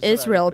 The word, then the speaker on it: Israel: We know the name Israel, pronounced in Hebrew as Israel,